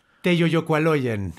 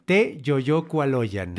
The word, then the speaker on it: Teyoyocualoyan: Sí, la sílaba tónica está en la penúltima sílaba en mexicas, en náhuatl. – Teyoyocualoyan. – Teyoyocualoyan. Teyoyocualoyan.